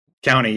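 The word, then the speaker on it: Donut County: Speaking of Annapurna, have you played Donut Country? – Yes, oh, Donut really County. Good. – County, you're right.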